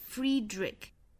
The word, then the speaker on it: Friedrich: 应该是 Friedrich Nietzsche Free 和 Ni 这两个音节的元音呢都是长音紧音的要发 E Free Ni 后面呢是一个松元音发 A 然后包括最后的这个 CH 字母组合在这里应该是发成一个 K 的发音所以 Friedrich